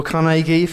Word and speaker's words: Carnegie: This is Andrew Carnegie, or Carnegie for the pronunciation that Harry said was right.